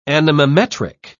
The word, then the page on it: anemometric: British English